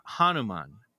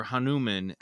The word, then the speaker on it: Hanuman: but it's Hanuman or Hanuman, H-A-N-U-M-A-N.